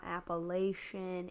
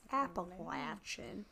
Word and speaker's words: Appalachian: It's not Appalachian. It's Appalachian.